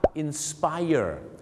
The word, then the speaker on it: inspire: คำไหนที่ลงท้ายด้วย ire อย่างเช่นคำว่า inspire นะครับฝรั่งก็จะต้องออกเสียงว่า inspire ถูกต้องแล้วครับ inspire ไม่ใช่ inspire นะครับวิธีการจำง่ายๆครับก็คืออยู่ในปาร์ตี้ร้อนดังกับไฟเออร์เธอสวยเซ็กซี่ร้อนดังกับไฟเออร์ fire ก็คือ fire